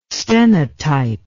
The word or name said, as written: stenotype